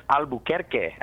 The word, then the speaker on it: Albuquerque: And the correct pronunciation is Albuquerque, actually.